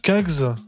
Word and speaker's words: Kacz: Et là, qu'est-ce qui passe, cette petite, cette petite fraîcheur Alors, gentille Kags. Alors, je prononce comme ça, ça s'écrit K-A-C-Z.